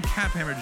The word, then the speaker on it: Kaphammer: But today, in his place, I have Austin Kaphammer joining me from Peeps and Plaid.